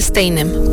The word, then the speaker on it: Steinem: Este jueves se confirmó la muerte de Gloria Steinem, periodista, escritora y una de las figuras más influyentes del movimiento feminista contemporáneo.